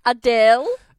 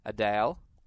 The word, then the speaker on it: Adele: No, it's Adele. – Adele. – Adele. Mm. Drag out the Dell.